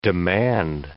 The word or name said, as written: demand